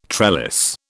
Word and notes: trellis: British English

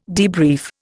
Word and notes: debrief: Indian

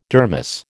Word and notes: dermis: American English

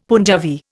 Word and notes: Punjabi: Indian

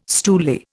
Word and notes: stoolie: Indian

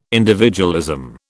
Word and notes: individualism: British English

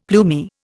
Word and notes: plumy: Indian

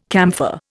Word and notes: camphor: Indian

